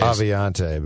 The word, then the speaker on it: Aviante: Initially, the Vikings would have Dakota Dozier start there and rotate on the first team uh, and alternate reps with Aviant Collins. Are we are we pronouncing the, the e on the end of Aviant Aviante? – I I've thought it was ways. Aviante, but Aviante Collins.